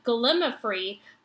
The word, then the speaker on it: gallimaufry: I would have written sooner, but expected that some other nobody would have let you know by now that the correct pronunciation of gallimaufry puts the emphasis on the third syllable, mao, not the second, lim as you've been say- saying it. So it's Gallimaufry.